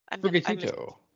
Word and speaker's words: Sprigatito: Sprigato. – Sprigatito. – Yes, Sprigatito, what you said.